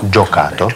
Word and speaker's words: giocato: Giocato. – Giocato. Lui non ha giocato. – Немножко. Вот если там написано о, значит, его надо произносить о.